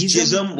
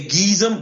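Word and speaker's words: GISM: I always want to say Gizm, but you pronounced them differently, didn't you? – No, I say gee. I think it actually is jism. Like I think it's jism, like sperm. But like cool people that I know say geezum because I think they're embarrassed that it is jism. And jism is usually spelled with a J. Jism is usually J-I-S-M.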